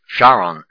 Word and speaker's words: Sharon: Actually, in the King James here, Sharon, not the accent on the second syllable.